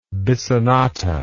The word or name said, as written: bissonata